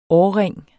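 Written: årring

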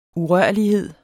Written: urørlighed

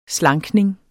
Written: slankning

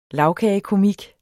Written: lagkagekomik

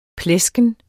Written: plesken

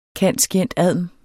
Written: cand.scient.adm.

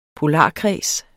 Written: polarkreds

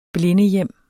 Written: blindehjem

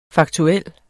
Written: faktuel

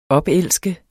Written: opelske

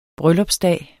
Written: bryllupsdag

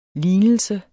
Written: lignelse

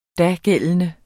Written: dagældende

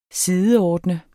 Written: SIDEORDNEDES

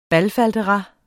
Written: BALFALDERAS

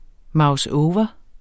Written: mouseover

